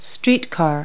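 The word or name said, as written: streetcar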